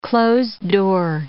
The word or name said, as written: closed-door